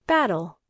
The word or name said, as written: battle